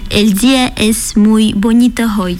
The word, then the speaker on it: el día es muy bonito hoy: El día es muy bonito hoy. – Ça veut dire la journée c'est très belle aujourd'hui. Se prononce el día es muy bonito hoy.